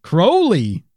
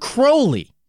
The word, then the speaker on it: Crowley: Crowley. – That's right. It's Aleister Crowley.